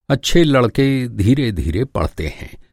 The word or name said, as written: धीरे-धीरे